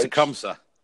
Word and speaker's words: Tecumseh: And I'm going to butcher the school's name, so I'm just going to spell it. But it's Dylan Day, and the school is TECUMSH. – Tecumseh. – There you go.